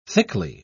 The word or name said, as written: thickly